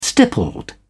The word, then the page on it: stippled: British English